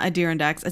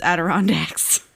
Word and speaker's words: Adirondacks: It's not Adirondacks. It's Adirondacks.